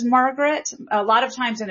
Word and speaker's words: Margaret: But Southerners often put more stress on the first syllable. So when he says Margaret, it's a little bit more like Margaret, where there's more stress on that first syllable, so that makes that word stand out even more.